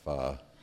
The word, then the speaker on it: pho: I can't eat any more pho. P-H-O is how that's pronounced, pho.